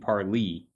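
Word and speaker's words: parley: P-A-R-L-A-Y. – Is it? – To bet or gamble. Oddly enough, though, they're pronouncing it parlay, but they mean parlee, which is the, a group, a gathering, a discussion or conference.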